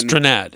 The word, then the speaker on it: Strnad: How do you pronounce Strnad.